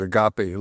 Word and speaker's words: agape: It's that agape or agape. We pronounce it agape, love.